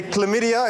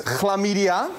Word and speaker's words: chlamydia: So where you would say chlamydia in English, in Dutch it's chlamydia.